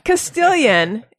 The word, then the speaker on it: Castilian: Castilian, am I saying it right? – You are.